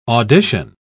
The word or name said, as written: audition